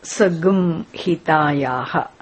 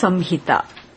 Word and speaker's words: संहिता: But whenever this nasal is followed by ha or ra or some letters, it should be pronounced as gum. So in chanting, sagum hitayaha. But otherwise, it is Samhita.